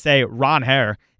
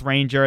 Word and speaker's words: Ranger: It was crazy to hear Ranger get up there at his Red Sox press conference and say, you know, I'm fine with Ranger, and that's how everybody's always pronounced it, but I actually go by Ron Hare, which was stunning to see.